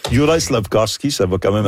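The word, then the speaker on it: Juraj: Personnellement, moi, j'ai de la difficulté avec Yurav Slavkovski. – Yurai.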